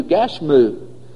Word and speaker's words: Gashmu: The Arabic pronunciation of the man's name is given in chapter 6 and verse 6, where we have the U, Gashmu.